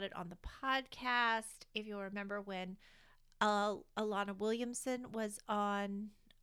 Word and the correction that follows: Elena: Elena Williamson, I always want to pronounce her name Alana. Elena Williamson was on the podcast.